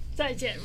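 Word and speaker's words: Zai Jian (再见): And let's see if I'm saying this right. Zai Jian. – Zai Jian, right. That's correct.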